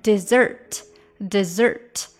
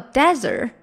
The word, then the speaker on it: dessert: 大家注意，这个甜品就是 dessert，dessert 啊，千万不要读成了 desert，那就变成沙漠啦。